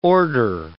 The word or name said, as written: order